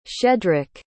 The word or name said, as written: shedrick